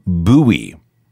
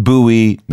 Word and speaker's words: Bowie: Oh, and let's be clear that's how you pronounce it Bowie. Not Bowie, not Bowie, Although guitarist Mick Ronson insisted on pronouncing it that way, it should be pronounced Bowie.